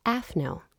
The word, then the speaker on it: AFNO: His name is Seth Dennis, and he runs a food advocacy group that you may or may not have heard of called AFNO.